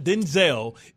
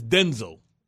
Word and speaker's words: Denzel: It's not Denzel, it's Denzel.